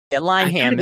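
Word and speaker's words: Linehan: I loved Peep Show back in the day, Inbetweeners, all the stuff that is unfortunately affiliated with Graham Lyon Ham, or however you say his name. I grew up watching that stuff. – Lyon Ham is fine.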